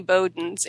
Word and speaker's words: Bowden: It's B-O-W-D-E-N. And the way I always spell that to people on the phone is, it's bow like what little girls wear in their hair, and den like where a bear lives.